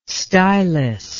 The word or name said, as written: stylist